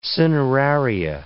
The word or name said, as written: cineraria